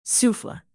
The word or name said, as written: souffle